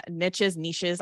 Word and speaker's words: niche: But basically Shannon is in one of the most interesting niches. I always say it wrong. Apparently it's niche.